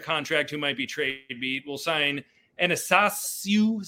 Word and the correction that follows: Athanasiou: Is that how you pronounce it? – Athanasiou. Andreas Athanasiou. – Andreas Athanasiou.